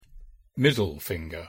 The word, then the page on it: middle finger: English